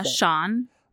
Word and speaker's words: Xuan: how would you pronounce X-U-A-N in Vietnamese? – Sean.